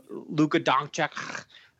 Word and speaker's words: Luka Doncic: You know, Luka Doncic. You're welcome. That's how you say his name, by the way. You have to sneeze as you say it.